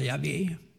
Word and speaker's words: Yahweh: Och när vi tittar efter vad det står i grundtexten, så står det de här hemlighetsfulla fyra bokstäverna som vi ibland brukar uttala Yahweh.